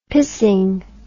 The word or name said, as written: pissing